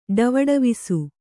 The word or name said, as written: ಟವಟವಿಸು